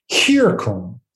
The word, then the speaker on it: circumveniri: And so as the commentaries had already figured out, it has to be pronounced circumveniri. Mm. Instead of a circum, he's trying to make it sound like the Greek letter chi.